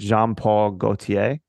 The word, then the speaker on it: Jean Paul Gaultier: And I, you know, like again, shout out to uh and Robbie, please correct me if I'm saying this wrong, but Jean Paul Gaultier.